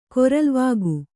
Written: ಕೊರಲ್ವಾಗು

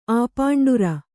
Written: ಆಪಾಂಡುರ

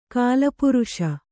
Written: ಕಾಲಪುರುಷ

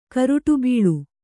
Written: ಕರುಟುಬೀಳು

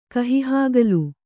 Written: ಕಹಿಹಾಗಲು

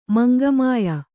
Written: ಮಂಗಮಾಯ

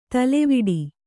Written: ತಲೆವೊಡೆ

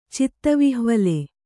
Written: ಚಿತ್ತವಿಹ್ವಲ

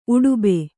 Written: ಉಡುಬೆ

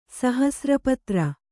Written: ಸಹಸ್ರಪತ್ರ

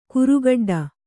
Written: ಕರಿಗಿಡ್ಡ

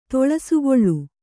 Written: ತೊಳಸುಗೊಳ್ಳು

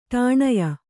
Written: ಠಾಣ್ಯ

